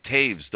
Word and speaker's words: Toews: They do say it Toews, don't they? – Yes, well, it's because he's German, and in Germany, W is pronounced like a V.